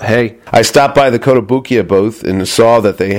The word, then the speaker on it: Kotobukiya: Nakamura, and it's Kotobukiya. I never say it right. – Kotobukiya, yes, that's okay. Correct.